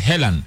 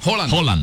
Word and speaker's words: Haaland: En Inglaterra dicen normal, debería ser Helan. Pero dicen es Haaland, y pero en noruego la doble A suena como una O. – Haaland. – Haaland. – Erling Haaland.